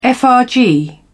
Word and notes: frg: British English

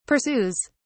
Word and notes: pursues: British English

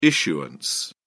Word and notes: issuance: British English

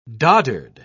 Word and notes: doddered: British English

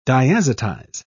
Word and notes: diazotize: British English

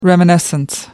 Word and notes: reminiscence: American English

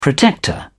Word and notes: protector: British English

